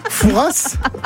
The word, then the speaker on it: Fouras: Fouras. – Je sais pas, on prononce pas le S apparemment.